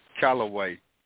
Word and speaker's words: Callaway: Callaway. – Callaway. – Like Cap Callaway.